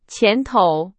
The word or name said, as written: 前头